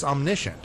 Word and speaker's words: omniscient: So another quality of God, the Father, and I mentioned it, I said it wrongly before, but it's omniscient.